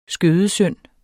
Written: skødesynd

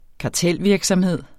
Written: kartelvirksomhed

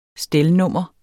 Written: stelnummer

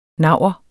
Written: naur

